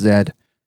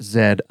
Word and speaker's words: Z: It's like, no, it's not pronounced Zed. – I think people say that because that's the way Getty and Alex pronounce it. So they probably assume that that's the way you're supposed to pronounce it. – Right. But that would make sense if we didn't have the letter Z also and we pronounce it Z.